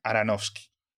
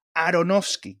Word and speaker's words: Aronofsky: Pero bueno, Matías, esta película, primero, dirigida por Darren Aronofsky, perdón, Aronofsky, que yo me acabo de enterar que es Aronofsky, toda la vida pronunciando Aronofsky.